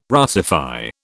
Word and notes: Russify: British English

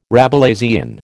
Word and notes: Rabelaisian: American English

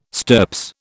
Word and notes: stirps: British English

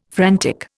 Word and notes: frantic: Indian

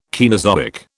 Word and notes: Caenozoic: British English

